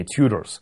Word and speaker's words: Tudors: Мы называем ее Тюдорами по-русски, хотя правильнее, конечно, с английской точки зрения делать ударение на первый слог. И действительно, по-английски они Tudors, точно так же, как и следующая династия Stuarts, Стюарты. Но в русской историографии принято говорить по французскому образцу, поэтому чаще можно услышать именно Тюдоры или, соответственно, Стюарты.